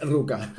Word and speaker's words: Rucka: Eso depende de cómo lo pronuncias. – No, es Rucka.